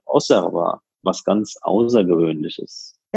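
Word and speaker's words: Auxerrois: Die Stadt wird ja mit X ausgesprochen, Auxerre. Der Wein, meines Wissens nach, wird er als Osserwa ausgesprochen, also X wie S.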